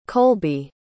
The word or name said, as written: colbey